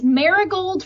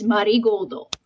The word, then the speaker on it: Marigold: So it's Marigold for like our Western palette, but the pronunciation they use is the Japanese pronunciation, which is Marigoldo.